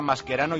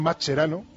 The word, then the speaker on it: Mascherano: pero que no sabes cómo se pronuncian Mascherano y Pochettino, que se dicen los dos con sonido Q, porque para eso son apellidos de origen italiano.